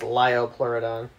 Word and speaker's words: Liopleurodon: It's pronounced Liopleurodon.